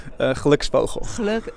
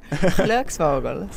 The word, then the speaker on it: Glücksvogel: Am I saying that anywhere near correctly? – Do you want me to pronounce it? Yeah, you pronounce it, I'll repeat it. – Glücksvogel. – Okay, I was nowhere near.